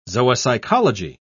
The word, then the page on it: zoopsychology: British English